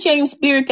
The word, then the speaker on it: James Beard: So yeah, that was James Bearden Award, wasn't it? – Yep. Yeah, James Beard. – James Beard, I said it wrong.